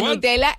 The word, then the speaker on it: Nutella: Porque Nutella es italiana, entonces entendemos que se pronuncia así, la nocilla española, ¿no? – Pues deberíamos decir nosotros Nutella.